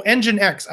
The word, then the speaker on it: NGINX: Engine oh Engine X.